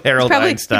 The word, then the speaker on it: Harold Einstein: Probably pronounced like the most famous person ever to have that name. – But isn't it spelled differently? Is Einstein spelled T-E-I-N? – Yeah. – It is? – Yeah. – Oh, okay. We'll go with Harold Einstein. – He probably pronounces it like Albert.